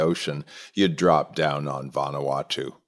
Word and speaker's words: Vanuatu: Vanuatu is the name of the country.